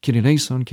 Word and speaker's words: Kyrie eleison: Or in Greek, Kyrie eleison, Kyrie eleison, Kyrie eleison. Kind of an emphasis on the first syllable, which kind of propels you on to the next.